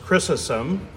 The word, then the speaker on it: Chrysostom: And every year during Easter, I think about reading this wonderful sermon from St. John Chrysostom, sometimes pronounced Chrysostom, which has been provided for you this morning as an insert in your bulletin.